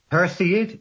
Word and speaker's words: Perseid: The Perseid meteor shower, and I hope I've pronounced that right, have I? – Perseid? Yes, Perseid.